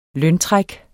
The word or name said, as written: løntræk